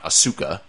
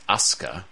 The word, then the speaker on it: Asuka: It's not Asuka. It's Asuka.